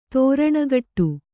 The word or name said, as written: ತೆರಣಿಗಟ್ಟು